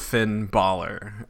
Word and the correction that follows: Finn Balor: Uh, can you Finn Balor? – Balor. Okay, sorry about that.